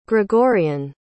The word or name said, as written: gregorian